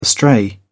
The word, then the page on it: astray: British English